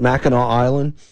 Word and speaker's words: Mackinac: I've seen it spelled with an A-C at the end. So, I don't know. I guess that's how they pronounce it. I've gone through several pronunciations of this thing. I think most people from Michigan pronounce it Mackinac Island.